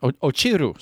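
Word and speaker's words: Ochiru (落ちる): Saru moki kara ochinu. I think that's how you say it. Ochiru. Sorry. So it's saru moki kara ochiru.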